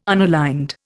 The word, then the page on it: unaligned: Indian